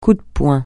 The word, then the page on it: coup de poing: French